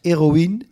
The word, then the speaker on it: Heroïne: Ehm, ja, mijn, mijn eh, favoriete restaurants eh, op, eh, op, dit moment, een van mijn favorieten is toch wel Heroin. Of eigenlijk moet je het uitspreken als Heroïne.